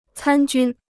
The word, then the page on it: 参军: cān jūn